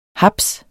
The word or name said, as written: HAPSEN